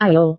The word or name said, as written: ayo